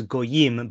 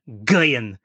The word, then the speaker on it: Goyim: That was the first time I heard derogatory statements made about Goyim to be pronounced as not as Goyim, but Goyim, as if one is spitting out a word with a bitter taste.